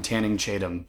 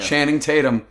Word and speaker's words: Channing Tatum: Every time, I almost call him Tanning Chatham. Every single time I say his name, like, Tanning Chatham. Yeah. Channing Tatum.